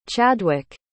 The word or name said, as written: Chadwyk